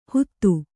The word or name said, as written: ಹುದು